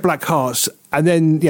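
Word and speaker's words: Blackhearts: is it Joan Jett and the Black Hearts, is it? – That's correct. – Or blackheads.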